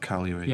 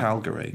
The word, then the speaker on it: Calgary: Do they pronounce it hard G in Canada? – Yes. – Not Calgary. Yeah. Calgary.